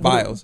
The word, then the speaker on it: Biles: Bios or Biles? – Biles. – B-I-L-E-S.